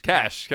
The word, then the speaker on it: cache: Cash.